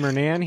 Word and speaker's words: Mernan: we have a new podcast member, Matthew Mernon. Did I pronounce that right? – It's actually Mernan, but it's a common mistake. I wouldn't worry about it. – I put the wrong emphases on the wrong syllable, which I've been known to.